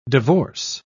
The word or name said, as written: divorce